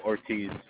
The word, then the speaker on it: Ortiz: Oh, Ortiz. – Could you spell that? – O-R-T-I-Z.